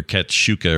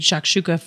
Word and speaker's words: shakshuka: So we didn't ever talk about your shakshuka or shakshuka.